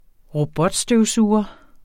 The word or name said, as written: robotstøvsuger